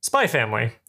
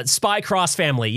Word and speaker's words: Spy x Family: Spy Family. You've seen some of that, right? – Oh, Spy X Family, the anime. – Yes. We don't pronounce the X here. – I see. Yes, Spy Cross Family.